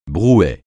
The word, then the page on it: brouet: francais